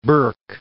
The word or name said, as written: Burk